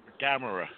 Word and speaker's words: Gamera: Turns into Gamera, whatever. How do you say that? You're the kaiju guy. – Gamera. – It is a camera, but it's with a G.